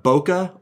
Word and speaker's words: bokeh: About half the people I know say bokeh, kind of like a bouquet of flowers.